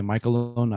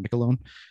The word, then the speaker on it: McLaughlin: I was also the first person apparently to say McLaughlin, not McLaughlin.